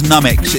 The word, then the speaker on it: Nummix: I think it's pronounced Nummix. It's N-U-M-M-I-X. A bit like comics, but more numb.